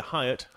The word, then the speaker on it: Hyatt: Yeah, Hyatt.